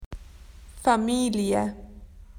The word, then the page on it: family: Australian English